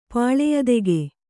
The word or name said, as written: ಪಾಳೆಯದೆಗೆ